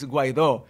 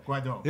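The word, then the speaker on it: Guaidó: Well, it's Guaido. – Guaido. – It's like, you know, Hermione in Harry Potter that says, Leviosa, no Leviosa.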